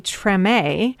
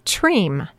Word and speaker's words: Treme: He has a monthly jam down at, well, people pronounce this place two different ways. If you lean toward the French or Quebecois, you would say Treme on Main Street in Islip. Or if you're just a grand Long Islander, you might say Treme, T-R-E-M-E.